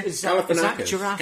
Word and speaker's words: Galifianakis: And Zach Galifianakis. – Yes. – How oh yes. – How do we pronounce his surname? – Galifianakis.